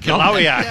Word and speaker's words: Kilauea: Kilauea.